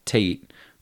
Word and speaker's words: Tayte: A backstory too on my name, Tate, it is spelled T-A-Y-T-E.